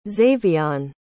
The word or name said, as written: zavion